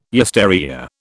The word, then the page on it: yesteryear: British English